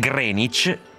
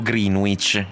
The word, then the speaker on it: Greenwich: Greenwich, pronuncia corretta del toponimo comunemente ed erroneamente pronunciato Greenwich.